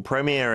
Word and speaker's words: premier: 미국 발음에서 이제 premier라고 하잖아요. 영어 발음 어떻게 되나요? – Premier. – 이제 영국의 프리미어리그도 프리미어리그라고 한다. – 그렇죠. 네. – 우리도 약간 영국 리그니까 그렇게 읽어야 되나요? – 네. – 네. Premier. 약간 다르네요.